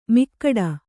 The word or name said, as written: ಮಿಕ್ಕಡ